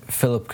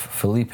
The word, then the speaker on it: Felipe: Number one is that at one point I said Philip Coutinho instead of Felipe Coutinho. That's just a pronunciation thing, but yeah, my bad on that.